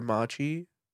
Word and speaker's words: Matchi: Mine is Obscure Machi.